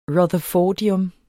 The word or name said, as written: rutherfordium